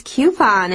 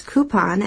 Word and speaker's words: coupon: And in the reading, you might have heard it read as coupon. And I wanted to let you guys know that both of those pronunciations are very common and very acceptable.